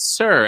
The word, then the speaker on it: SUR: It's spelled S-U-R, but and you say it Sir as if you're saying, hello, Sir.